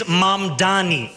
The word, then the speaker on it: Mamdani: The name is Mamdani. M-A-M-D-A-N-I. You should learn how to say it.